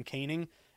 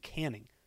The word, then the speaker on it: Canning: So for tonight's uh, interleague series opener, Angels going with Griffin Canning, six and three, 4.29 ERA. And it's funny, actually, I used to say Griffin Canning, and someone DM'd me and they said, uh, "You idiot, it's Griffin Canning."